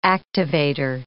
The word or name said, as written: activator